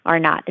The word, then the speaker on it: R-naught: It's like R sub-zero. R-naught is how it's pronounced.